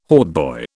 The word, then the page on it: hautboy: British English